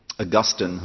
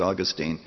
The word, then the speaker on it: Augustine: Augustine, whom we often quote, sometimes pronounced Augustine, had a mistress.